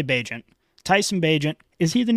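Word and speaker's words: Bagent: I think is it pronounced Bajent? I think it's Bajent.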